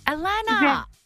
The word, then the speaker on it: Alana: It's Alana. And it's I get double N-A-H. I get every letter of the alphabet put at the end of it, but it's just A-L-A-N-A. – Alana. – Very easy. – Sorry, yep. – Did I say it right? Alana, Alana. – Yeah, Alana, yes.